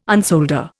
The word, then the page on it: unsolder: Indian